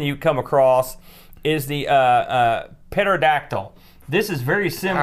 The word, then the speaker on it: pterodactyl: The P is silent. You never learned that in school, pterodactyl?